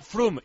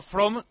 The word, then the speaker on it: From: No entiendo cómo Pedrito Martín aún nos ha dicho que From se pronuncia Frum y no From, como decís siempre.